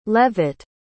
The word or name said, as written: leavitt